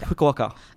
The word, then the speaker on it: Fukuoka: Is that Fukuoka or Fukuoka? – Fukuoka, yep. Don't you be challenging my Japanese pronunciation there.